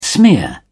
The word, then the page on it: smear: British English